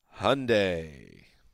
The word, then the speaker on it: Hyundai: Time now for Draft Facts, driven by Hyundai. – Rhymes with Sunday.